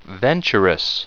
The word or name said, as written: venturous